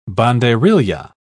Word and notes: banderilla: British English

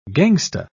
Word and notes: gangsta: British English

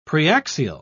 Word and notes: preaxial: British English

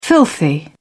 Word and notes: filthy: British English

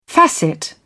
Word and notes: facet: British English

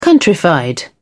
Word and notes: countrified: British English

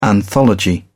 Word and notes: anthology: British English

